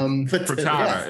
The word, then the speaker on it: Fertitta: You have to wonder whether he is prepping for a career with the Rockets and whether he's included in a trade to, I don't know how to pronounce that name, Tillman Fertitta's team. – Fitta, yeah. – Fertitta. It's called a Fertitta.